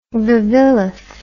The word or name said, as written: Vavilov